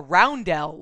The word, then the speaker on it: Rondel: Now, are you saying rondel like a type of a ship or a roundel like a circular thing? – Roundel, but it's spelled R-O-N-D-E-L-L.